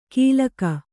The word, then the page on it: ಕೀಲಕ: kīlaka